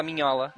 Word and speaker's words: Mignola: Mignola, pode falar Mignola. – Mignola? – É, pode falar Mignola. É como se pronuncia.